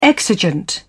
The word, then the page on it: exigent: British English